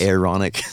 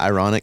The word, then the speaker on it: Aaronic: But what people do is people think that the Aaronic priesthood was Aaronic. – Yeah. – Not ironic. – I just think that's a fun way to say it. – It is. You said it right.